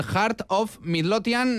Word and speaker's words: Heart of Midlothian: Bueno, Rafa, pues este era el sonido del sorteo que se ha celebrado esta misma tarde, el Heart of Midlothian.